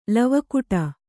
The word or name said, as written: ಲವಕುಟ